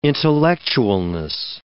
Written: intellectualness

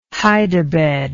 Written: hide-a-bed

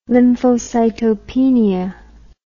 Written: lymphocytopenia

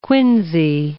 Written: quinsy

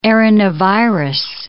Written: arenavirus

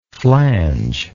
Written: flangeless

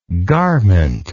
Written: garmentless

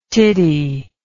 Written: titty

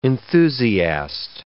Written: enthusiast

